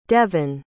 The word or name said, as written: devin